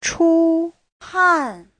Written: chu1han4

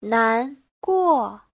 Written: nan2guo4

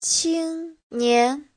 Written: qing1nian2

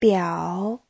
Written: biao3

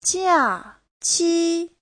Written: jia4qi1

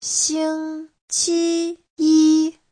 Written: xing1qi1yi1

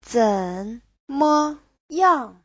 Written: zen3me5yang4

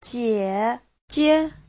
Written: jie3jie5

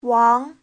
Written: wang2

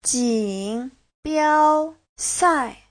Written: jin3biao1sai4

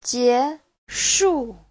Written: jie2shu4

